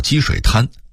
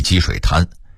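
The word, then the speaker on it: 积水潭: 北京有个地名叫积水滩，潭的读音是二声潭，但北京人一般都念一声滩，即积水滩，好像那地方当年是积水形成的沙滩似的。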